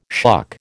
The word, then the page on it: shuck: American English